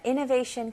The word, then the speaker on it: innovation: Innovation 그리고 renovation. They sound very similar, don't they? 네, 첫 번째 단어부터 볼게요. 네, 발음 연습부터 해볼게요. Innovation. 마지막은 SH 소리로 shun. Innovation.